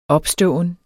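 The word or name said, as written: opståen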